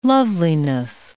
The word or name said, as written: loveliness